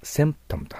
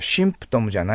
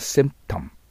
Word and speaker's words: symptom: そうすると背の口で巣ですからセンプトムと発音します。いいですか？シンプトムじゃないですよ。センプトムです。そしてね、打足ながら M の発音は口をしっかり閉じるんです。 M。だから、 symptom。そうすると、まあ、いわゆるかっこいい発音になってくると思います。